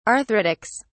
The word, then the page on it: arthritics: British English